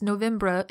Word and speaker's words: Novembre: I'm pronouncing it wrong. It's Novembre in the French way, but I just can't. My mouth doesn't work that way as an English speaker. I can't do the RE thing.